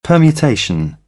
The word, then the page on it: permutation: British English; ɜː